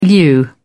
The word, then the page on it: lieu: British English